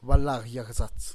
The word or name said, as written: balagyagzats